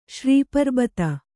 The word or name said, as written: ಶ್ರೀಪರ್ಬತ